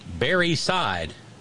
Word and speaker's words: Barreseid: B-A-R-R-E-S-E-I-D. It is pronounced Barry Side.